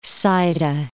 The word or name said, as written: Saida